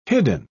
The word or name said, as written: hidden